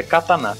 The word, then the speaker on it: katana: Falando nisso, a pronúncia correta é katana. Tem um, a, uhum, a última pronúncia é tão forte, para aqueles que, que não falam corretamente.